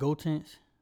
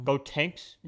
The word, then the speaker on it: Gotenks: I like Gotenks. – Gotenks? – Yeah.